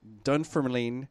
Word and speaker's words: Dunfermline: That was obviously a Scottish band because their name is the Scottish Enlightenment out of Dunfermline, Scotland. Do you think I pronounced that right? – Oh, absolutely.